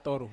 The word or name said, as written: toru